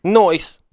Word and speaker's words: Noyes: Noyce. – N-O-Y-E-S. – Noyce.